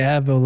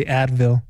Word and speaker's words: Avril: Every time I see her name, I want to say Advil. – I know. Exactly. you say it right. There's an R in there. You got to get that right.